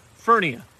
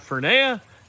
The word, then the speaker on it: Furnia: It rhymes with hernia, she said. That makes it easy. Furnia. Not Fernia, not Fernia, not Fernia, not Fernia.